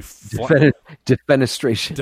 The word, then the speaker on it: defenestration: This one, by the way, is going to fill our defenestration card, which is somebody... – Defenestration. – Defenestration is how you pronounce that word. Thank you.